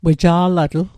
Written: weja'latl

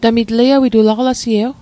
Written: aqalasie'w

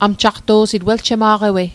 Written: amjaqto'sit

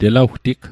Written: telawtig

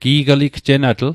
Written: gi'gligjenatl

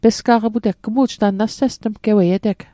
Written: pesga'qiputeg

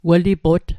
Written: welipot

